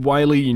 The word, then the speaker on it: Wily: It's spelled W-I-L-Y, which looks like Willy, but apparently it's Wily.